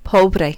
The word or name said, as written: pobre